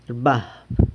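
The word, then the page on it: rbab: San Lucas Quiaviní Zapotec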